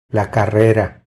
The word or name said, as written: La carrera